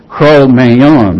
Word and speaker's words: Cro-Magnon: People like Neanderthal or the contemporary And until I did this study, for all my entire career, I was pronouncing this Cro-Magnon confidently. It's sort of like filet mignon, so it's Cro-Magnon man.